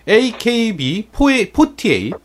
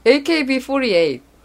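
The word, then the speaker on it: AKB48: 예, 우리가 비할 의도는 없었고, 그냥 아키, 아키바라고 읽기가 편해서 그렇게 읽은 건데, 네. 일단은 뭐, AKB 48.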